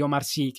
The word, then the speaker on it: Omar Sy: vedi, di, vabbè, Omar Sai, Omar Sy, che è l'attore protagonista, è Lupin nero su Netflix.